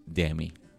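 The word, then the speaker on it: Demme: Eh, y dirigida por Jonathan Demi, que se pronuncia así, Demi.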